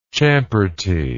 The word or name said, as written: champertor